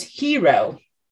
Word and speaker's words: gyro: It's G-Y-R-O. – Gyro? – Gyro. But. – Gyro. What, how do people say it? It's hero. Like he row.